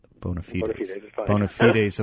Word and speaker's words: bona fides: Bona fides of William Buckley.